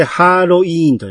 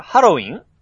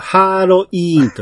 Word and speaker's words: 七剣守護神: これでハーロイーンと読みます。あ、書いてあるじゃんか。ハロ、ハロウィン違う。これはハーロイーンと読むんです。